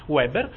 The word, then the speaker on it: Weber: Uno dei grandi sociologi di cui parleremo occasionalmente, che è Max Weber, e vi prego di pronunciarlo con la V perché è una delle poche cose che mi danno proprio un senso fisico di disagio, Weber.